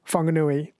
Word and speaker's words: Whanganui: I think the WH is pronounced F, like Whakatane is WH, Whanganui WH instead of F.